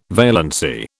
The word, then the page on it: valency: British English